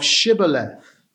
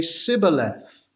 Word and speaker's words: Shibboleth: The Ephraimites, it appears, could not pronounce the sh of Shibboleth. They could only say Sibboleth.